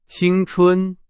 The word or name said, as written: 青春